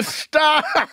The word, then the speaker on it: Gstaad: Yeah, and believe it or not, Neil, Stod starts with a G. There's a silent G. – What? – Yeah, you, I believe the spelling, Joelle, if you check, is G-S-T-A-A-D, if I'm not mistaken.